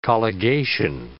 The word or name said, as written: colligation